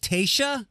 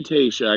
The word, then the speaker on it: Tayshia: And if I'm not mistaken, and I don't know if I'm pronouncing it correctly, T-A-Y-S-H-I-A. We'll go with Tayshia.